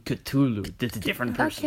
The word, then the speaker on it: Cthulhu: If you pronounce it properly, it's Cthulhu.